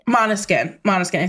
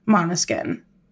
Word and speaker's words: Måneskin: Måneskin. It's Dutch for moonlight. They're an Italian rock band, but the name comes from the Dutch word for moonlight. Måneskin.